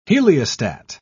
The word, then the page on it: heliostat: British English